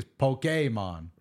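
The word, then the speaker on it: Pokémon: But the way they want it pronounced officially is Pokemon.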